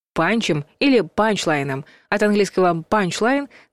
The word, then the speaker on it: панчлайн: Писать нужно слитно, а ударение ставить на первый слог. «Панчлайн». Хотя чаще используют короткий вариант «панч», где с ударением точно не ошибиться.